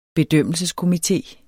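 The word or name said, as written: bedømmelseskomité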